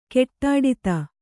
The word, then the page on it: ಗಡ್ಡಾದ: gaḍḍāda